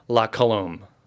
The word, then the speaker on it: La Colombe: So you say comb, like la comb, but then he adds an O in between the M and the L. So la colombe.